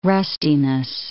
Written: rustiness

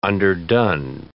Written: underdone

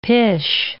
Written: pish

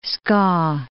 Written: Skaw, The

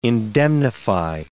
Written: indemnify

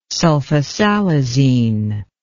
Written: sulfasalazine

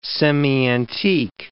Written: semi-antique

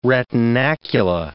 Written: retinacula

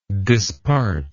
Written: dispartment

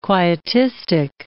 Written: quietistic

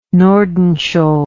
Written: nordenskjold

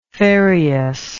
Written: ferreous

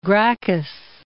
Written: Gracchus

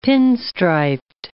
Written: pin-striped

